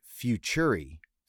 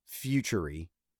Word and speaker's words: Futuri: Futuri, Futuri, F U T U R I.